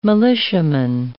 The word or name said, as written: militiaman